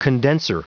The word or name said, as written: condenser